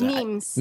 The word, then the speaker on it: meme: And I thought it would be interesting to get your take on some well-known author quotes, these things that we see in, how do you pronounce the word M-E-M-E? Is it mem or memes? – Memes. – Memes. – Like, kind of like jeans.